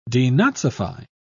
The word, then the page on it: denazify: British English